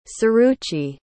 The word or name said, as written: Suruchi